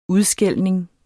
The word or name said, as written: udskældning